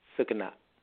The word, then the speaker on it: Sucanat: Sucanat.